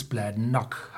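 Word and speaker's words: Bladnoch: Und Gälisch betont man typischerweise auf der zweiten Silbe. Also müsste es Bladnock heißen. Das TH wie noch. Also Bladnock. Und das Vinaya müsste wie Naya ausgesprochen werden. Das A nicht so sehr wie ein A im Britischen, sondern eher wie ein A äh, im Deutschen. Also Bladnock Naya.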